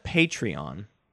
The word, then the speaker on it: Patreon: I called it Patreon because that's how it's pronounced. – Yeah, but you said patron.